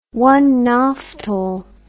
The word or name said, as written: onemolite